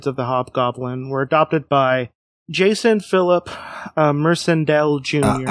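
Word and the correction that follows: Macendale: I think it's Mackendale. – Mackendale. – At least that's how they pronounce it on the 90s cartoon. – Mackendale. Yeah, I was putting an R in there for no reason.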